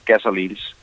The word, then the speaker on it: Gas Orleans: Du er aktuel med et nyt projekt, som I kalder, ja, er det Gas Orleans, det skal udtales som? – Ja, jeg synes, det lyder perfekt, som du siger det der, Gas Orleans.